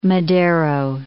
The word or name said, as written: Madero